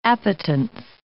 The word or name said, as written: appetence